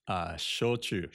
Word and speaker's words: shochu: Shochu.